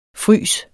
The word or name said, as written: frys